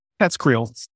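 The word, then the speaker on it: TESCREAL: How do we say it? – Teskriel. – There we go.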